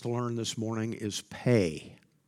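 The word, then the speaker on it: Pe: Pay, the P in pay, the Hebrew letter pay, sounds, you pronounce the P just like you would in peace, in the word peace.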